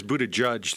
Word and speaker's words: Buttigieg: I think that's Buttigieg, the way Buttigieg? – It's pronounced.